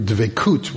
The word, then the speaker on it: dveikut: It's what in Hebrew / Yiddish they would call dveikus, which is the Yiddish pronunciation of the Hebrew dveikut, which is clingingness, connectedness to God.